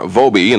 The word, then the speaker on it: Vobbe: Vobe, and the last name is spelled V-O-B-B-E, and send it to our address here in Westland, Michigan.